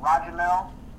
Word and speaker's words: Ra'Jahmel: So you can either find me on Facebook at Roger Mel White.